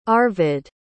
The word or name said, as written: Arvid